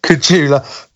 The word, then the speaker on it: Kujala: He's joined by a Finnish driver, Patrick Kujala. Did you tell me to pronounce it? – Kujula.